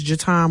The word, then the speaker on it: Jataun: How can they give your Facebook so they can My Facebook is Jatun Morgan. – Spell that. – J-A-T-A-U-N, last name Morgan, M-O-R-G-A-N.